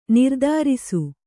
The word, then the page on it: ನಿರ್ದಾರಿಸು: nirdārisu